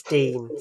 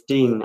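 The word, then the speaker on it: Digne: I mean, sometimes they're really, really good, and as you saw in that game, I mean, your player Dingne, how do you pronounce his name? – It's Dean. – So it's Dean.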